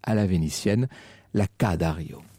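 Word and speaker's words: Ca' Dario: Et nous poursuivons notre promenade vénitienne et nous sommes maintenant précisément al Palazzo Dario ou la Ca Dario.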